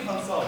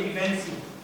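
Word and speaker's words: evansi: محدش يقول ايبانسار. في ناس بتقول ايبانسار هي ايبانسي. تريبانسوما ايبانسي دي اكتر تريبانسوما بتيجي في الجبال وبتعمل مرض اسمه السرف.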